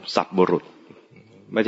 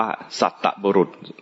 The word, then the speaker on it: สัตบุรุษ: สัตบุรุษไม่ใช่หาคนทั่วไปด้วยนะหาสัตบุรุษคำนี้อ่านว่าสัตบุรุษคนทั่วไปอ่านว่าสัตตบุรุษอ่านผิดสัตตะแปลว่า 7